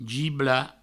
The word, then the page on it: giblar: gibla